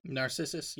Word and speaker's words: Narcissus: Narcissus.